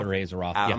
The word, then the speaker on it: Azeroth: The latest World of Warcraft expansion, Battle for, and I apologize if I mispronounce this, if you know how to pronounce it, please correct me, Battle for Azeroth? – Azeroth. Yeah, I'm going to go with Azeroth. – That's pretty close, yep.